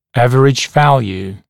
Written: average value

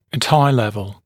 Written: at eye level

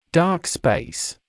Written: dark space

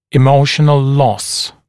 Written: emotional loss

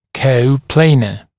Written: coplanar

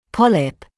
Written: polyp

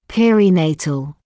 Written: perinatal

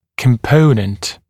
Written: component

